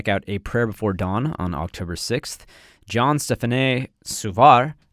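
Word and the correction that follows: Jean-Stéphane: I believe it's Jean Stephanie, but I will allow how you said it.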